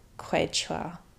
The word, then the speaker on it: Quechua: And most children adopted from Bolivia have an Indigenous background, be it Aymara or Quechua.